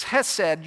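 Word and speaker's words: chesed (חסד): Now, if you pronounce that as chesed, or you drop the C and pronounce it as chesed, you're not pronouncing it correctly. If you're not probably spitting on the person in front of you, you're not really pronouncing it correctly. It's chesed.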